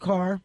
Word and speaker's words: Kerr: And when the crew came to Biarritz, the cinematographer, who I believe was married to the actress Deborah Carr, spelled K-E-R-R, but pronounced Carr, he came equipped with his surfboard and his black rubber, what do they call it?